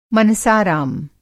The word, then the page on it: मनसाराम: Hindi